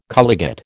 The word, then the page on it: colligate: American English